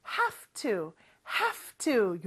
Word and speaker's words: have to: have가 F 발음으로 변해요. 그래서 have to 요렇게 발음이 됩니다.